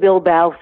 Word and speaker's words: Bilbao: So, yeah, I saw Chain's going to be playing at the Bilbo, I'm not sure if you pronounce that. Bilbo. – It was at the Bilbao Film Festival, and then it was May 2nd.